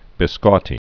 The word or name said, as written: biscotti